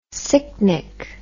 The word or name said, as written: sicknick